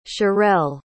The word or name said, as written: cherrelle